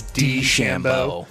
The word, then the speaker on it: DeChambeau: And I found out the other day I was saying it wrong when I said DeChambeau. – It's DeChambeau. – Just the D. Not day.